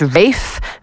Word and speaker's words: Ralph: Now, although it's spelled his first name R-A-L-P-H, looks like Ralph. He says this is Rafe, very posh way, Rafe Fiennes.